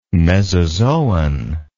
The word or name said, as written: mesozoan